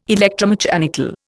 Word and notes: electromechanical: Indian

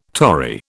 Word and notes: Tory: British English

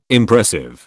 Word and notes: Impressive: British English

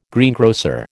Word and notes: greengrocer: American English

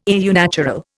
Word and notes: au naturel: Indian

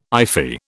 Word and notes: iffy: British English

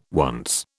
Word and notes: once: British English

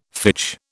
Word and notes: fitch: British English

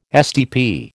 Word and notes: STP: American English

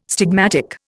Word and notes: stigmatic: Indian

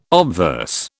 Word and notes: obverse: British English

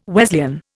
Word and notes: Wesleyan: Indian

